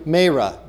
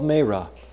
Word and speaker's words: Marah: And when they came to Marah, now you might have to excuse me, for all of my life I have heard that pronounced Mara, and I've pronounced it that way. And according to my phonetic Bible, the pronunciation is Marah. It's a long A.